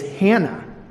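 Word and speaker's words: Hannah (Ἅννα): But it's on the heels of this prophecy that we meet a woman named Anna. Now, it's interesting that we call her Anna because even in the Greek, the proper pronunciation of her name is Hannah.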